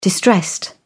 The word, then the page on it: distressed: British English